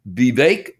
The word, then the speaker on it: Vivek: Its name is Vivek. That's what they just said. It rhymes with cake.